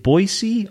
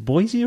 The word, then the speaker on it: Boise: Uh, Le Bois means the forest, uh, but most people in the Boise area use a soft S. Uh, if you're not from Boise, they use a hard F or even a Z.